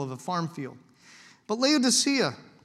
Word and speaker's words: Laodicea: We've been to Laodicea. You can see, I keep saying it wrong, Laodicea.